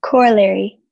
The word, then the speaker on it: corollary: Corollary. C-O-R-E-L-L-A-R-Y. Corollary. – So that goes. The word is corollary. – Are there any alternate pronunciations? – Corollary or corollary. – Corollary. C-O-R-O-L-L-A-R-Y. Corollary. – Gori, your word is portentous. – Portentous. P-O-R-T-E-N-T-O-U-S.